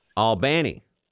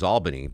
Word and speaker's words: Albany: Well, I grew up knowing the capital of New York was Albany, but I pronounced the southern city Albany, kind of like Cairo, Georgia. – Absolutely. Yeah. Yep. – Not Cairo, Egypt, Cairo, Georgia, where they make the syrup, Longoria.